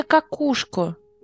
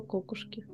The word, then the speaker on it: кокушки: Нет, ударение кокушку. Извините, пожалуйста, я правда не знала. Я думала кокушки.